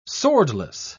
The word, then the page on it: swordless: British English